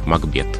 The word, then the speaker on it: Макбет: то мы должны говорить «Макбет».